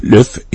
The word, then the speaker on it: Luv': Løf, L-U-V, som det staves, men det udtales nærmest L-Y-F, Løf, var ingen undtagelse.